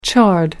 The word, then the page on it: chard: American English